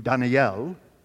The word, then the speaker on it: Daniel: And he asked me, he said, Daniel, and by the way, that's how my name is pronounced in Hebrew, Daniel.